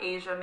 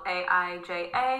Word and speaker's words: Aija: So you can find me on Instagram, TikTok, Facebook, and YouTube at Aija Mayrock. My first name is spelled Aija and then Mayrock.